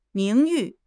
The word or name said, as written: 名誉